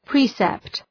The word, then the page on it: precept: ‘pri:sept